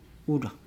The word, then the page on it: ula: Nukuoro